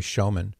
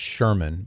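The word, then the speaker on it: Schoeman: His name is Roy Shoeman. It might be pronounced Sherman, but it's S-C-H-O-E-M-A-N.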